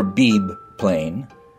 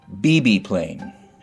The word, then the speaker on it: Beebe Plain: Beebe Plain. Never Beebe Plain. Beebe Plain.